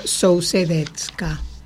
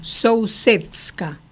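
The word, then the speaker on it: Sousedská: Era una Sousedetska. Sousedska, yo le pronuncio mal.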